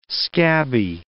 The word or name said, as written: scabby